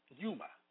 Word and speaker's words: pneuma: The Greek word for spirit is pneuma, pronounced pneuma, meaning a current of air, breath, or breeze, an angel, demon, or divine God.